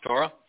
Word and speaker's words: Torah: One disputes that Torah is pronounced Torah, and the O sound in Torah is from a wa, or that shalom is pronounced with an O, and that O is also from a wa.